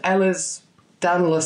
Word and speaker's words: Ehlers-Danlos: But really, it came about because you had, now I can't say this word, Ehlers-Danlos syndrome. Is that how you say it? – That's exactly how you say it, yeah.